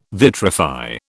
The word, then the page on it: vitrify: British English